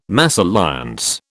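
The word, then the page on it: mésalliance: British English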